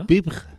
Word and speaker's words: Survivre: Se pronunciará Survivre o una cosa así, ¿no? – Survivre. Sí, lo pronuncias muy bien.